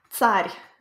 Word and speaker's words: Tsar: It's actually pronounced Tsar. It's using the ts letter that we looked at.